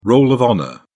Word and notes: roll of honour: British English

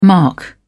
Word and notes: mark: British English

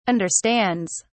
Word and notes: understands: British English

